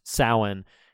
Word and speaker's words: Samhain: That's actually his name is short for Samhain, which is it's pronounced Samhain, and it's spelled Samhain, Samhein, which is the traditional Celtic festival from which a lot of Halloween lore extends.